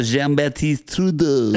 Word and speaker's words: Jean-Baptiste Trudeau: Say it like the French say it. – Jean-Baptiste Trudeau. – You have to roll the R with the... Jean-Baptiste. – He's French Canadian, so. – And so Jean-Baptiste Trudeau.